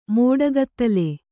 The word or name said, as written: ಮೋಡಗತ್ತಲೆ